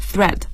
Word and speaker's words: thread: 这个 thread 是注意一下它的发音 th 这样的声音 thread。